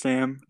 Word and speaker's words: Sam: So my name's just Sam.